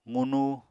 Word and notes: qunu: apatani

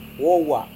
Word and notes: wowa: Serewen

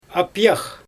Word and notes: apijax: Xyzyl

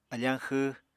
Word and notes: afañ xw: Apatani

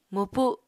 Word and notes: mwpu7: Apatani

